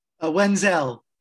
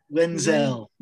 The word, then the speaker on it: Wenzel: Wenzel, like that's good. Wenzel. – Wenzel, like Denzel, right? – That's right. Just like Denzel.